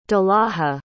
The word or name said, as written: dalaja